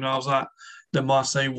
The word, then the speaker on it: Marseille: Marseille? – Marseille, yeah.